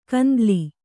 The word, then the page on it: ಕಂದಲಿ: kandali